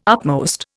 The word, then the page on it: upmost: Indian